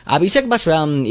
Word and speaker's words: Abhishek Bachchan: Abhishek Bachchan y Aishwarya Rai, muy bien, así más auténtico, pues tenían un romance en secreto.